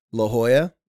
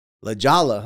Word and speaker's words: La Jolla: Yeah, or in my near my hometown of San Diego, California, there's a town called La Jolla. The J is silent, but whenever tourists would come in town, they'd be like, hey, how do I get to La Jolla?